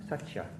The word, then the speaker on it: Satya: How would you pronounce that word, Gil? Satya? – Satya in Pali. – Satya. – Satya in Pali, Satya in Sanskrit.